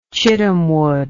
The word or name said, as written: chittamwood